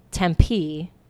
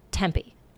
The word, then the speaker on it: Tempe: And Tempe, not Tempe. – Tempe? – Tempe. Yeah. – Tempe. Yeah. I've always called it Tempe. – Good. Most people say Tempe. – No. – And Prescott. – Tempe.